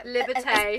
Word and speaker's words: Liberté: And what facilities do you have in Liberté? Am I saying that right? – Yeah. – Liberté.